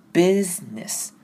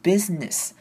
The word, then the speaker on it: business: 那这边的 s 的念法不太一样哦，前面的 s i n business 的 s 呢要念成 z 的声音，并且把 s 后面的 i 的 i 的声音几乎是省略掉，念成 business。有没有发现没有念出 busy？那个声音出来，只是念成 business，那后面的 ss 结尾呢，就是念 s 的一般的念法 s 的声音，所以事业这个字正确的念法是 business。